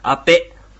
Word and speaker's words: アペ: アペ。 – アペ。 – アペ。 – はい。アペです。アペというふうに、濁って発音されるときも時々ありますが、アペと、発音します。